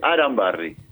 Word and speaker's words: Arambarri: Porque me corrigió el conductor, se dice Arambarri.